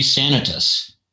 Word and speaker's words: Sanitas: So Sanitas is the standard normal pronunciation, which is the Spanish pronunciation, accent on the second to the last syllable, which is common in Colorado. However, it's not named a Spanish name at all. It's named after a sanatorium at the base, which is Latin. So the correct pronunciation would be Sanitas.